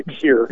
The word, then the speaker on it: Pierre: I live in Pierre, which is spelt like Pierre, but we say Pierre.